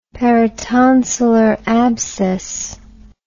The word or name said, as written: peritonsillar abscess